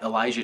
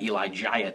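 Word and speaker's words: Elijiah: And I guess if you were to pronounce it phonetically, it would be at Elijah T. Because my mom decided that it would be a good idea to put another I in my name. So it's E-L-I-J-I-A-H-T.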